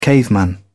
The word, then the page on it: caveman: British English